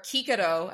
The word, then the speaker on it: Cicero: Cicero. Don't even, or Cicero, as you're supposed to say his name in Latin.